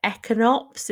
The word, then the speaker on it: Echinops: I want to say Echinops. Is that the one? – Yeah. – Am I saying that right? – It's the little tiny. Yeah, you are.